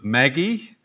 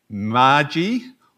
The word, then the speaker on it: Magi: The reading was particularly difficult because we had to work out how to say the word M-A-G-I. Was it Maggie, Margie, or Margi, or something else?